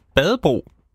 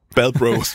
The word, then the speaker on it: Badbro: Men inden da, så skal vi altså lige høre Badebro, eller Badbro. – Badbro. – Man vil udtale den sådan. – Badbros.